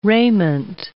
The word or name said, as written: raiment